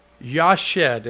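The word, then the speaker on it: yashab: In the Old Testament, the Hebrew, there is one basic word for abide, and that is the word yashed. That's the way it would be pronounced, and I'll spell it for you. It's Y-A-S-H-A-B.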